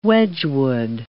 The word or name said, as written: Wedgwood